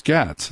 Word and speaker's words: Gaetz: And that's what these government officials told Congressman Gaetz, G-A-E-T-Z. Isn't it weird? I don't think I ever heard his name pronounced, but it's Gaetz. It's got to be Gaetz, right?